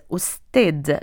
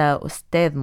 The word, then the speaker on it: أستاذ: أستاذ مساعد، أستاذ مشارك، أستاذ كرسي، وأستاذ زائر. هي كلمة فارسية وغير عربية، ولم تورد هذه الكلمة في الشعر الجاهلي، وتنطق بضم الهمزة وتعني المهارة في العمل أو الماهر في شيء أو العالم به.